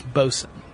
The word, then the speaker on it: boatswain: Just like the person who's in charge of overseeing sailor discipline on a ship is spelled boatswain, but it's pronounced bosun.